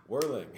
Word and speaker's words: Werling: Weirling.